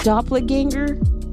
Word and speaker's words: doppelganger: And Naomi is like my, is it called doppelganger? Is that how you pronounce it? – Yes.